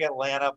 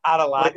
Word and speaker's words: Atalanta: It's spelled, it looks like Atlanta, but it's not Atlanta. Athletica or Lafita. – Atalanta or whatever. Yeah. – Atalanta. – It's like Atlanta with an A in the middle of it.